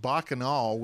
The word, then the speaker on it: Bacchanal: Well, Scott, first off, it's pronounced Bacchanal, which is Roman for orgy.